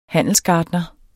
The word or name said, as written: handelsgartner